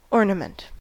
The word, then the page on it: ornament: American English; noun